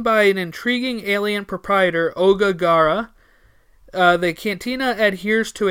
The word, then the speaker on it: Oga: Oga. O-G-A. O-G-A. That's how I would pronounce it, right? Oga? – I guess so. I can't imagine how else.